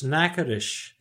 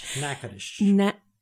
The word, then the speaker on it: Natchitoches: It's Natchitoches. – It's like N-A-K-A-T-O-S-H. Natchitoches.